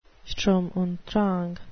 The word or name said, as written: sturm und drang